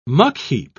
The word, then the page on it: muckheap: British English